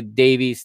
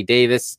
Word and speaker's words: Davies: Chris Mapham and Joe Roden are the center back pairing with Ben Davies or Davis, depending if you want the American or the UK pronunciation. We in the US say Davies to this, and in the UK they say Davis.